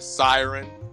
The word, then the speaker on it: Psyren: So our first manga series that we're all going to chew into is going to be called Siren. It's spelled P-S-Y-R-E-N.